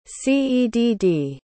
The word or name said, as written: cedd